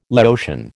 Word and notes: Laotian: American English

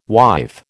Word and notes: wife: American English

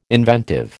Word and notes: inventive: American English

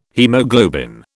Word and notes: haemoglobin: British English

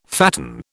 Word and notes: fatten: British English

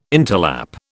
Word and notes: interlap: British English

